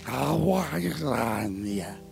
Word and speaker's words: Gamaliel: He studied at the feet of Gamaliel. That's how we assume it's pronounced. Who knows? It's pronounced Gamaliel.